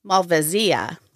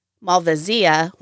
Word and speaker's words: Malvasia: The Italians definitely say Malvasia. In an article on Texas Wine Lover website, Dr. Carl Hudson says that some people say Malvasia while others prefer Malvasia. Malvasia bianca from Italy and Croatia are also mentioned.